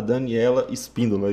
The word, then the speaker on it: Daniela Espíndola: Hoje nós temos uma psicóloga aqui como convidada, Daniela Espíndola, é isso mesmo? – Isso mesmo.